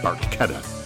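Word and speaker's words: Barchetta: Neil Peart read it and thought, what a great idea for a song, and in Beyond the Lighted Stage was very quick to point out that it's pronounced Barquetta.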